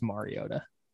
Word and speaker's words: Mariota: Well, then you need to pronounce his name right. It's Mariota.